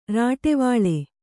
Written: ರಾಟವಾಳೆ

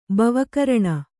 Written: ಬವಕರಣ

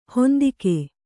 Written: ಹಿಂದೆಗೆ

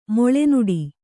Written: ಮಲೆನಾಡು

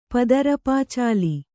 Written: ಪದರುಪಚಾಲಿ

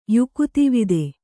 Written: ಯುಕುತಿವಿದ

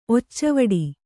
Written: ಒಚ್ಚವಡಿ

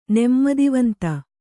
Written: ನೆಮ್ಮದಿವಂತ

